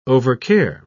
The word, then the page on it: overcare: British English